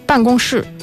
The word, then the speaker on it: 办公室: 办公室。 – 办公室。So, 这个办公室有大有亮。